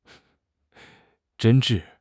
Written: 真挚

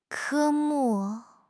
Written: 科目

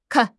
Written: kan4

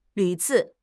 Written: 屡次

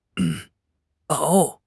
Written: 熬

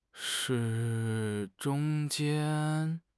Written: zhong1 jian1

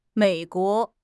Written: Mei3 guo2

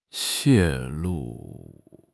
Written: 泄露

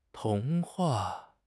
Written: 童话

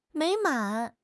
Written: 美满

